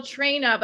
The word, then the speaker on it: Traina: And like Michelle Traina, but people will say Melissa Trainer or Trainer after I say it.